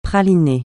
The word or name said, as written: praliné